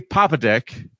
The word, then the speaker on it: Popadick: Papadick. – Yep, and you are pronouncing that correctly. – Yes, I am. – It is Papadick is his name. – P-O-P-A-D-I-C-K.